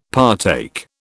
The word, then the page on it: partake: British English